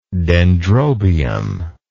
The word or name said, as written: dendrobium